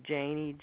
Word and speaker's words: Janey: Janey, J-A-N-E-Y, Davidson. – Thank you very much. And Davidson is spelled D-A-V-I-D-S-O-N? – Yes.